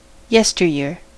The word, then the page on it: jadis: France; /ʒa.dis/